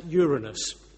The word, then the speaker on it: Uranus: And he and others had been looking at the planet, I'm going to pronounce it Uranus.